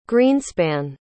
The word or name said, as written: greenspan